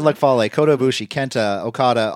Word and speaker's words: Okada: Come on, you got to say it like the Japanese announcers. Kazuchika Okada. No, Okada.